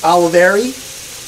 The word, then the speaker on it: Olivieri: Yeah, legend says it that Pat and Harry Olivieri, am I saying that right? – Olivieri.